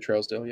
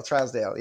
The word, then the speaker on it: Trousdale: I mean, like, is that how you pronounce his name? – Yeah, Jerry Trousdale, yeah. – Trousdale.